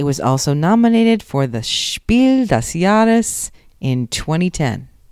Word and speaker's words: Spiel des Jahres: My husband told me I should just say Spiel des Jahres. – Yeah, that's how the board gamers say it. – But I speak a little bit of German, so I'm saying it properly.